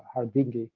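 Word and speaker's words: Harguindeguy: He met with a very notoriously anti-Semitic general called Albano Hardingay, who, if you mispronounce it, it's Hardingi. Albano Hardingay was the Minister of Interior, so at the forefront of supervising torture.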